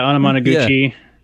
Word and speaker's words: Anamanaguchi: And I don't know if you're familiar with I always say this band's name wrong. Anamanaguchi. – Oh yeah. Yeah, Anamanaguchi. Yeah.